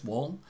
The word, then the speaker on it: Wahl: His name's Chris Wahl,